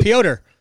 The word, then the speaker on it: Piotr: Piotr. Just call him Piotr. – Piotr. Yeah, Piotr. That's right, exactly. Not Peter, Piotr.